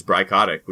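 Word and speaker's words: Bry Kotyk: You can look up my name, which is Bry Kotick, which is spelled BRYKOTYK, on Instagram, or perhaps Tumblr.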